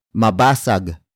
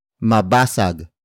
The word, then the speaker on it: mabasag: might the next word is mabasag mabasag Mabasag. Mabasag is spelled as M A B A S A G.